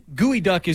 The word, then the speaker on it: geoduck: And gooey duck is spelled G-E-O-D-U-C-K. It looks like geoduck.